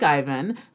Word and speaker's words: Eivin: Secondly, I really like the boy name Ivan, spelled E-I-V-I-N, which is pronounced like Ivan, but has a different meaning.